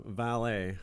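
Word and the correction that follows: Valle: If I'm saying it right. Or Vaya. – Oh, what is it? V-A-L-L. – E. – Yeah, it's Vaya.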